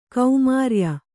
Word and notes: ಕೌಮಾರ್ಯ: kaumārya

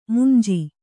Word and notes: ಮುಂಜಿ: munji

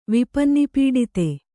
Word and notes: ವಿಪನ್ನಿಪೀಡಿತೆ: kannada

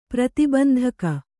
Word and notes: ಪ್ರತಿಬಂಧಕ: prati bandhaka

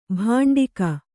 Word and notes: ಭಾಂಡಿಕ: bhāṇḍik

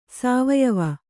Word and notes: ಸಾವಯವ: sāvayava

